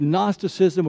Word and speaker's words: Gnosticism: That's Gnosticism with a silent capital G, okay?